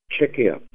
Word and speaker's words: Czechia: The name that could soon be on Czech products everywhere is Czechia. – That's C-Z-E-C-H-I-A. – Czechia. – Thanks to the Czech ambassador to the U.S., Petr Gondolovich, for that pronunciation.